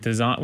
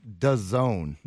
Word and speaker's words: DAZN: Alvarez's contract with DAZN with the exclusive contract with design uh uh-huh.